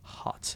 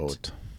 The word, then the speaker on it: Haute: Hot. H-A-U-T-E. Hot. – Oat. – Hot. – Oat like oatmeal. – Oat. – Oat like, Indiana. You ever heard of like oat cuisine? – Hmm. Yeah. – But it's spelled like hot. It's pronounced.